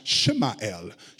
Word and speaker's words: Samuel: When you say his name, though, if you were to pronounce his name in the Hebrew native tongue, it is actually pronounced Shemael, Shemael.